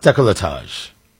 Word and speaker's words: décolletage: Right, so I pronounce it décolletage. I think it's pronounced décolletage. – What? – What? That's it. Listen, that's how it's pronounced.